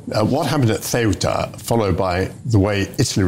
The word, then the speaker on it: Ceuta: No, I mean, first of all, it's finally great to see here somebody who can pronounce Ceuta correctly, because we've had so many ways of doing it in recent times.